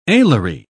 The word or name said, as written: alary